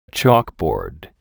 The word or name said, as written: chalkboard